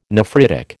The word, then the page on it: nephritic: American English